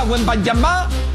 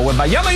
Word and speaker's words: Wembanyama: No llegaba a Wembanyama, porque así se pronuncia de manera correcta, por cierto. Víctor Wembanyama, pero en Estados Unidos ya lo bautizaron como Wembanyama.